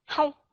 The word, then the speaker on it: how: cái âm ao đọc cho rõ ràng là ao how how giống cái người đó chứ không có đọc là hâu hâu